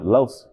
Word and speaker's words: Los: Los. It's spelled L-O-S. Like Los Angeles, Los. So that's the, you know what I'm saying?